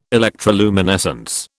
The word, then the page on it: electroluminescence: British English